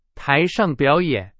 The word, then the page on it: 台上: Chinese